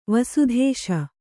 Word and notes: ವಸುಧೇಶ: Kannada

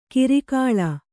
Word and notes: ಕಿರಿಕಾಳಾ: Kannada

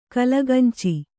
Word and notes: ಕಲಗಂಚು: kalagancu

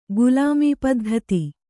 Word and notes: ಗುಲಾಮಪದ್ಧತಿ: kannada

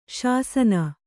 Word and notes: ಶಾಸನ: śasana

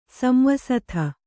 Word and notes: ಸಂವಸಥ: Kannada

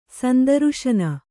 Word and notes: ಸಂದರುಶನ: sandaruśana

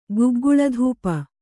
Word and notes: ಗುಗ್ಗುಳಧೂಪ: gugguḷa dhūpa

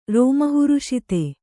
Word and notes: ರೋಮಹರ್ಷಿತ: rōma harṣita

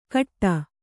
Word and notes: ಖಟ್ಟ: Kannada

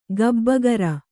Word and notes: ಗಬ್ಬಗರ: kannada

